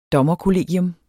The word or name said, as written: dommerkollegium